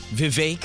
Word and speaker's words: Vivek: That's Jill told me to pronounce it. Cake. It's cake. – Vivek.